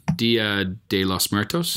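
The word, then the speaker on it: Día de los Muertos: Dia de los Muertos. Mm-hmm. Did I say that right? – Yeah.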